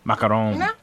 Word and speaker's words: macaron: Did I pronounce it wrong? Macaron. – No. You pronounce it right.